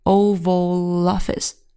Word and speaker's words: Oval Office: Oval 它表示椭圆形的，因为美国总统的办公室是椭圆形的，所以呢，首字母都大写的 Oval Office 就特指美国总统办公室。大家这里要稍微注意一下， Oval Office 中间它有一个连读 Oval Office，很好。